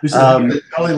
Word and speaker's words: Halley: Halley like Valley. – Halley likes Halley, okay. – Like Valley, I asked them how to pronounce it.